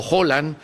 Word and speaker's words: Haaland: a un país trascendental que tuvo a Haaland, o Haaland, como dicen ellos que se pronuncia, como correcto,